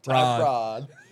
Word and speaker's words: Tyrod: T-Y-R-O-D. Tyrod. – Tyrod. – Tell me I'm wrong. Is it not T-Y-R-O-D? – It is, but that's That's Tyrod, brother. Yeah. Y'all just can't pronounce it.